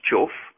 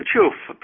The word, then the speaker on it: chough: While its black plumage identifies it as a crow, the chough, pronounced chuff, yeah, it's because it's spelt chug, that's why it's telling me that. The chuff, pronounced chuff, has a red bill and legs unlike any other member of the crow family.